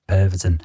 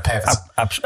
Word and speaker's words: Pervitin: Pervitin. – Yay, there we go, Pervitin.